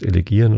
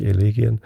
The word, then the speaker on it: Elegien: Also Duineser Elegien habe ich mittlerweile verstanden, dass ich sogar Elegien falsch ausgesprochen habe. Das heißt Elegien und nicht Elegien.